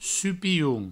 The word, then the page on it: supilhon: provençal; supihoun; supioun